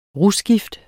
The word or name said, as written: rusgift